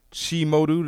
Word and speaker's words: Chi Modu: We have our guest here tonight, Chi Modu.